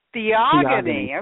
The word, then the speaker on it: Theogony: Is it Theogony? – Theogony. Okay, that's exactly how it's spelled.